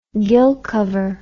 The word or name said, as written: gill cover